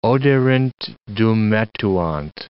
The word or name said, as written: oderint dum metuant